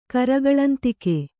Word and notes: ಕರಗಳಂತಿಗೆ: kannada

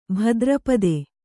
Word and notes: ಭಾದ್ರಪದೆ: bhādrapade

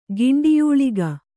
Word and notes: ಗಿಂಡಿಯೂಳಿಗ: Kannada

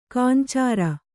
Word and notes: ಕಾಂಚಾರ: kāncāra

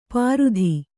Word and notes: ಪಾರುಧಿ: pārudhi; Kannada

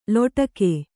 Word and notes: ಲೊಟಕೆ: loṭake

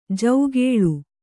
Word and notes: ಜೌಗೇಳು: jaugēḷu

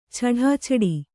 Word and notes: ಛಢಾಛಡಿ: chaḍāchaḍi

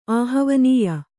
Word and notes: ಆಹವನೀಯ: āhavanīya; Kannada

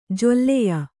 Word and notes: ಜೊಲ್ಲೆಯ: jolleya

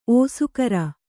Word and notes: ಓಸುಗರ: ōsugara

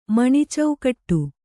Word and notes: ಮಣಿಚೌಕಟ್ಟು: Kannada

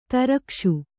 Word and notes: ದ್ರಾಕ್ಷೆ: drākṣe

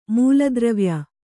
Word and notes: ಮೂಲದ್ರವ್ಯ: mūla dravya

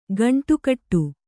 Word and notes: ಖಂಡಗಟ್ಟು: Kannada

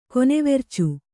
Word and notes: ಕೊನೆವೆರ್ಚು: konevercu

